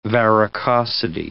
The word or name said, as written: varicosity